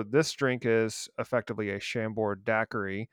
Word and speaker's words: Chambord: And I'm going to butcher the pronunciation, but I think it is called Chambord, with a D at the end. I believe that's French.